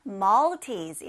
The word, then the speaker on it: Maltese: 네, 말티즈를 영어로 Maltese 이렇게 발음합니다. L 발음 살려서요.